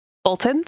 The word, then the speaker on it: Bolton: Bolton sounds weird. Pronouncing it correctly. Bolton.